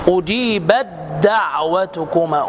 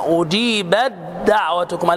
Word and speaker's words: أجيبت دعوتكما: التاء مع الدال العكس، أجيبت دعوتكما، أجيبت دعوتكما، لا تقل أجيبت دعوتكما خطأ ادغام، أجيبت دعوتكما في سورة يونس.